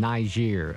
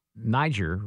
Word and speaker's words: Niger: Niger. I'm kind of focused on the pronunciation of that country because I always thought it was Niger, right? Now it's Niger, but these things change from time to time.